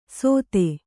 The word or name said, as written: ಸೊಥೆ